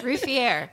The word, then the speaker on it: Ruffieux: Ruffier.